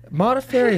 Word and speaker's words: Modafferi: Modafferi is not that difficult.